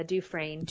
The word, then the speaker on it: Dufresne: Am I pronouncing his name correctly? You even did put his name in phonetically for us. – Yeah, Dufresne.